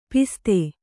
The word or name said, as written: ಪಸದ